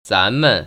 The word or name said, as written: 咱们